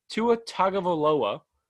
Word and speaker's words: Tua Tagovailoa: Tua Tagovailoa, I think I said that right? – Yeah.